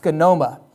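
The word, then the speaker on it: skenoma (σκήνωμα): This term in the Greek is pronounced skenoma, which is used here in verses 13 and verse 14.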